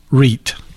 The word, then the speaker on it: REIT: That's well, a, Nick, that's a darn good question because you need to understand the, the workings of a, a writ. you need to, it's pronounced REIT. Okay.